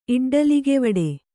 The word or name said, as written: ಇಡ್ಡಲಿಗೆವಡೆ